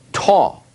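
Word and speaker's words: to (τό): Now, in the Greek, these are pronounced ho, he, to.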